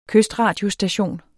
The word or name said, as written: KYSTRADIOSTATION